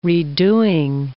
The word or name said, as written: redoing